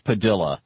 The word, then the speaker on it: Padilla: As predicted, the Supreme Court has backed away from deciding whether or not Jose Padilla, how he prefers his name be pronounced, P-A-D-I-L-L-A, Jose Padilla,